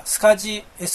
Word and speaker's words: SCSI: そのうち出てくると思いますが、デイジーチェーンでよく使われるのは、スカジー、 SCSI と書いてスカジーと読みますが、それでよく使われます。